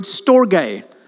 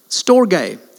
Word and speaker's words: storge: Thirdly, the third kind of love, which is kind of related to phileo, is the word storge. Storge, there it is. Not storge, that would be very Eastern Cape pronunciation. Storge.